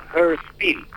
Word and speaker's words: Hörspiel: Luego el lenguaje cinematográfico aportó para esa búsqueda de lo que en Alemania se llama aún hoy en día Hörspiel, o juego para el oído, que se escribe H-O con diéresis R-S-P-I-E-L, que lo pueden ver en la página que ya les remití.